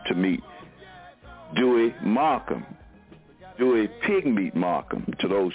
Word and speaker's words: Pigmeat: you can look up Pigmeat, just like it's spelled, P-I-G-M-E-A-T, Pigmeat Markham, M-A-R-K-H-A-M.